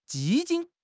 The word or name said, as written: 基金